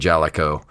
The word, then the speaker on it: Jaleco: released for the arcades by Jaleco, or Jaleco, however you pronounce that these days. I always pronounced it Jaleco.